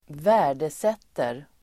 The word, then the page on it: värdesätter: [²v'ä:r_deset:er]